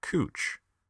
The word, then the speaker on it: Couch: Fact The C O U C H in Couch Street is actually pronounced Cooch. Status True.